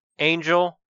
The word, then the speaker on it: Angeal: There was Angel, which is spelled A-N-G-E-A-L, and Genesis.